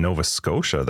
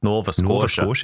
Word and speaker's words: Nova Scotia: I think somebody messaged me that was in Nova Scotia that was dealing with them too. Crazy. – He said it wrong. Nova Scotia.